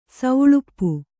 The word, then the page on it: ಸೆಳಪು: seḷapu; Kannada